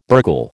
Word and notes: burgle: American English

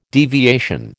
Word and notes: deviation: American English

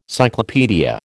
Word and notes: cyclopaedia: American English

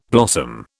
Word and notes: blossom: British English

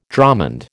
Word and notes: dromond: American English

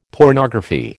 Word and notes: pornography: American English

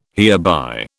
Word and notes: hereby: British English

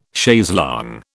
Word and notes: chaise longue: British English

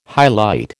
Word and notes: highlight: American English

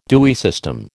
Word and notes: Dewey system: American English

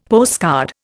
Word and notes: postcard: Indian

